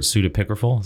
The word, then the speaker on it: pseudepigraphal: Yeah, and they're all, how is the word, pseudepigraphal? Is that how you say it? – Yeah, like, that sounds great to me.